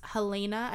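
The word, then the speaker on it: Halyna: Her name was Helena, I believe is how it's pronounced.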